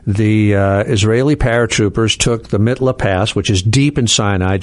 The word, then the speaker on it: Mitla Pass: And so there's at one point in the book, if you don't understand 56, you can't understand 67, the Mikvah Pass. Did I say that right? – Mitla Pass.